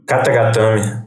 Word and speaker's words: Katagatame: Como que pronuncia esse bagulho aqui, mano? – Katagatame. – É, Katagatame. Katagatame.